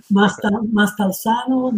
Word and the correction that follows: Maniscalco: Maniscalco. Is that how you say it? – There, that might, hey, you did it best, I think.